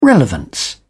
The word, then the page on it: relevance: British English